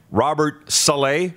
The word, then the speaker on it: Robert Saleh: Hey, the Jets coach, pardon me, he was the defensive coordinator in L.A., Robert Saleh.